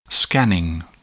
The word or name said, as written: scanning